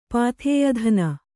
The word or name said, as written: ಪಾಥೇಯಧನ